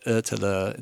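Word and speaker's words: Ötillö: It's called Otillo, pronounced like Otillo in Swedish.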